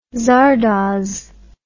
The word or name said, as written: zardoz